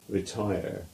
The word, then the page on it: Retire: English